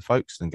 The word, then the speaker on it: Folkestone: I genuinely thought that we'd have more trouble with the Folkestone game coming up. I said it right, Pete, didn't I? Did I say it right? Folkestone. Yeah. Oh, wow. I'm learning. – You got it.